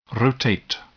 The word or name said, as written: rotate